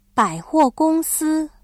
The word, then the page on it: 百货公司: bǎihuògōngsī